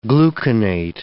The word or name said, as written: gluconate